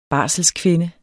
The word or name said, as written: barselskvinde